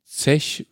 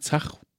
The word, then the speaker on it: Zach: Äh, Zech, Zech, Zech, würde man Zech vielleicht sagen? Zach? – Zech. Zech Zech.